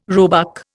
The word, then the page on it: roebuck: Indian